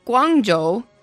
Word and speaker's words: Guangzhou: Hangzhou, Guangzhou, Chengdu.